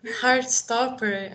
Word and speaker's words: Heartstopper: Heartstopper, acho que isso, é assim que fala.